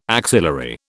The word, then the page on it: axillary: British English